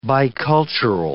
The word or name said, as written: bicultural